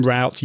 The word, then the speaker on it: route: So Gary says, we Yankees use both pronunciations. So you use root and route.